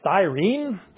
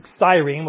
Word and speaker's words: Cyrene: Doesn't mean that this guy is necessarily Jewish, but the idea is from Cyrene, or Cyrene, if you want to do it in the Latin kind of thing, but Cyrene, we'll just go with that pronunciation, is in modern-day Libya.